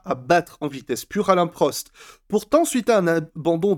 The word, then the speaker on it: Alain Prost: Oui, ça se prononce Alain Prost.